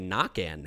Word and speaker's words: Naquin: And we got Tyler Naquin, or as we should say, Nakan now, because technically that's how it's actually pronounced.